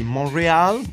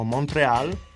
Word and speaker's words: Montreal: È un articolo che parla della città canadese di Montréal, o Montreal, la pronuncia secondo te è la seconda, la più giusta.